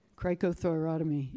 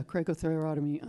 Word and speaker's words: cricothyrotomy: cricothyrotomy, yeah, cricothyrotomy on somebody um, in the field and saved their life.